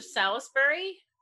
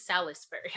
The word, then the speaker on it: Salisbury: Like a steak. – So it, but it's spelled Salisbury. Is that how, is it? Mm-hmm. – But that's so what you spell Salisbury, like the steak.